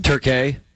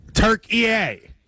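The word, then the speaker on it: Türkiye: Turkey. – No, they're Turkey to me. – It's Turk-A-A. – No, Turk-A-A. – Turk-E-A. – Turk-E-A. – Turk-E-A, right? Turk-E-A. It's not just Turk-A. It's now three syllables. Turk-E-A.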